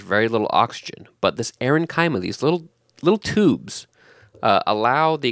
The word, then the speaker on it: aerenchyma: erenchyma. I think that's how it's pronounced. It's spelled A-E-R-E-N-C-H-Y-M-A. Erenchyma, erenchyma, erenchyma. Not sure. I'm going to say erenchyma.